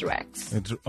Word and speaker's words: RASHX: It's RASHX, but the SH is silent, so it's Rex.